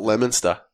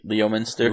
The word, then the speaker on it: Leominster: How about Leominster? – Leominster.